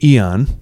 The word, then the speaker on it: Yen: And she tells me that her name is, it's pronounced Eon, but it's spelled like the currency in Japan, yen, Y-E-N, but it's Eon.